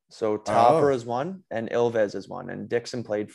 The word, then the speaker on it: Ilves: So I was going to call it Eels. – Yeah. It's pronounced Ilves. It's like a bobcat or a lynx.